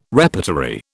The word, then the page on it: repertory: British English